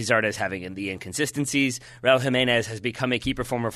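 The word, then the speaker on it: Jiménez: So I've gone with up front, Raul Jimenez. – Okay. – Jimenez, excuse me. Got to learn how to pronounce it perfectly.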